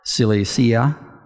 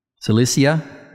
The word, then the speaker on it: Cilicia: Verse 21, afterward I went to the regions of Syria and Sicilia. I don't think I said that right. Cilicia. Cilicia? Yeah, that's correct.